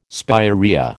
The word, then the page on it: spirea: American English